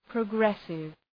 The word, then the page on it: progressive: prə’gresıv